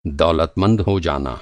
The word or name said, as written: दौलतमंद